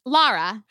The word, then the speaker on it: Lara: Lara. – How you spelling that, sis? – L-A-R-A.